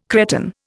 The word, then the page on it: Cretan: Indian